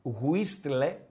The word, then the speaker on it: whistle: Γράφεται με ταφ, whistle, έτσι γράφεται, αλλά αυτοί που τα αγγλικά τους έχουν ξεφύγει και είναι σε άλλο level, ε, γνωρίζουν ότι αυτό το ΤΑΦ δεν διαβάζεται.